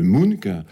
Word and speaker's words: Munch: Donc, Munch, M-U-N-C-H, qu'on prononce en général Munch, qui est un artiste d'origine norvégienne que on connaît bien, bien sûr, par ce fameux tableau qui s'appelle Le Cri.